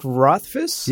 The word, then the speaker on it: Rothfuss: I've not read Name of the Wind by Patrick Rothfuss.